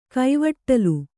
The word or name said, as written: ಕೈವಟ್ಟಲ್